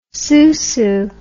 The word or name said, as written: sousou